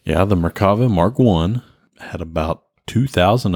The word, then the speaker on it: Merkava: Merkava.